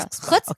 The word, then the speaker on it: chutzpah: You know, they both had like chutzpah. How do you say it? – Chutzpah. I got you, girl. Chutzpah.